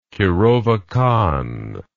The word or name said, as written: kirovakan